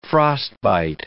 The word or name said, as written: frostbite